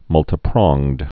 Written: multipronged

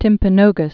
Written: Mount Timpanogos

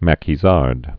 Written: Maquisard's

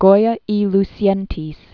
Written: Francisco Jose de Goya y Lucientes

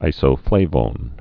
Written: isoflavones

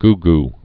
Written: goo-goo

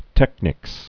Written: technics

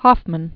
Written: Hoffmann

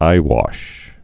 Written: eyewashes

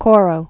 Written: Coro

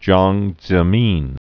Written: Jiang Zemin

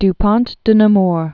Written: Du Pont de Nemours

